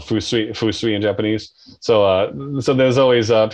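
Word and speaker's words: Fusui (風水): One more time on the pronunciation. – Fusui. – Fusui. Awesome. – Yeah. Wind is fu. And then sui is water.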